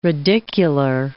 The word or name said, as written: radicular